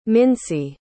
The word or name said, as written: mincy